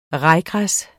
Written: rajgræs

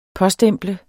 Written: påstemple

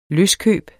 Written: benytter

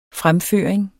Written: fremføring

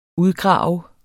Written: udgrave

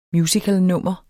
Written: musicalnummer

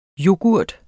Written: Yoghurten

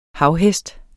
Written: havhest

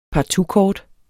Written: partoutkort

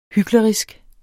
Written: hyklerisk

